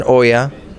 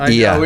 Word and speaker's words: Oia: It's written Oya, it's pronounced IA. – We always have that problem with that. And I would say I'm on IA Village, not Oya.